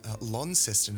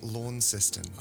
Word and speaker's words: Launceston: Launceston, it's pronounced, not Launceston.